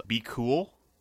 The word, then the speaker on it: BQL: I mean, also worth looking up is Be Cool. Even though it's spelled BQL.